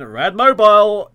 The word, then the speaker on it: Radmobile: You should pronounce a Radmobile like you would do Batmobile.